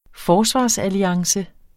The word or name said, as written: FORSVARSALLIANCE